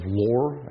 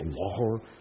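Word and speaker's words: Loughor: Lohr. I believe that's how you say it, Lohr.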